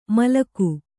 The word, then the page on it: ಮಾಲಕ: mālaka